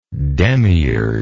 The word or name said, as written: demiurgically